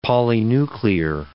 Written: polynuclear

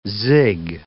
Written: zig

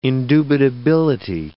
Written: indubitability

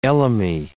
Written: elemi

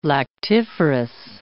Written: lactiferous

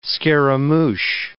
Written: scaramouche

